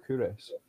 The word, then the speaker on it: Culross: There's a small town called Couris, but it's spelled Culross, and I spend quite a lot of time there just because it's lovely.